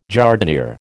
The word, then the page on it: jardinière: American English